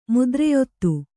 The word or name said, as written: ಮುದ್ರೆಯೊತ್ತು